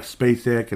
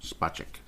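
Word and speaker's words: Špaček: Did I say it right this time? I hope. You know, I was saying it right. And then I went like Spacek and Spachek and, you know, Spachek. Yes. Yes, Spachek.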